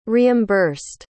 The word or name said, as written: reimbursed